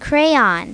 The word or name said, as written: crayon